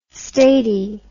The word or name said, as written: statie